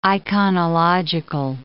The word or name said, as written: iconological